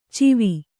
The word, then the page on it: ಚಿವಿ: civi